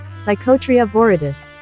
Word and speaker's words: Psychotria viridis: So ayahuasca, which sometimes people refer to as the vine or the tea, is a brew made from the leaves of a Psychotera verdis shrub. I might have to put the pronunciation in there. – Psychotria viridis.